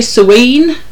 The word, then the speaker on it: Samhain: Now, I'm going to say Sawain. – Sawain. – Yeah. It's spelt Samhain, but it's definitely not pronounced that way, because that is that Celtic?